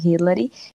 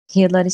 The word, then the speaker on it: Rhilary: Bom, gente, meu Instagram é Amanda under, underline Hillary. Hillary se escreve R-H-I-L-A-R-Y.